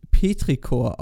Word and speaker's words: Petrichor: Petrichor spricht man übrigens Petrichor aus, da es aus dem Griechischen kommt und dort in der Regel ch wie K ausgesprochen wird.